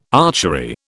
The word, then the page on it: archery: British English